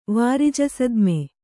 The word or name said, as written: ವಾರಿಜಸದ್ಮೆ